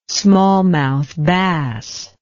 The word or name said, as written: smallmouth bass